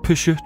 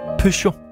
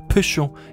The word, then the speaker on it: Peugeot: Jeg fik sagt Peugeot, det hedder det ikke, det hedder Peugeot. Pø som i pølse, cho som i chokolade, Peugeot.